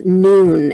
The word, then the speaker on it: Nuun: is from a company called Noon, and they spell it N-U-U-N.